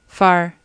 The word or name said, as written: far